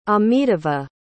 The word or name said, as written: amitava